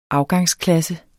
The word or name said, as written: afgangsklasse